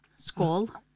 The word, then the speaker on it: Skål: What do you guys say? Skål? Skål. Or skål? – Skål. – Skål. Mm-hmm. Got to say it right. – Yep. – The proper inflection, proper tonal inflection.